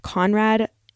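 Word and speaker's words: Coenraad: So this guy, I'm going to butcher his name, but it's like Conrad, but spelled like Dutch. Conrad Johannes van Houten discovered that he could separate the cocoa butter from like the chocolate solid to make cocoa powder.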